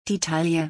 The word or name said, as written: die Taille